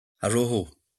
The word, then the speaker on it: Araujo: I mean, Araujo, have I said that right? God, because he's one that's... – Araujo, yeah.